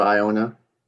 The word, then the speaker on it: Iona: It's not Ionia. – There's Ionia, Michigan, close to me. That's why I say it. So, wow. – Okay. – How do you say it? We talk at bracket breakdowns, and Mark doesn't even know that an Ionia school exists. – Ionia? – Yeah, Iona. He's adding an N.